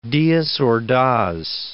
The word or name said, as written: Diaz Ordaz